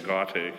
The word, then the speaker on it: Gaudete: And what a great segue into the third week of Advent, known as Gaudete. It's your choir. How do you say it? Gaudete, yes, thank you.